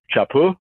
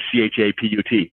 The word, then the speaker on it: Chaput: And there's a guy that I've read some of, and his name is Archbishop Charles, and I think the French pronunciation would be Chaput, but it's Chaput.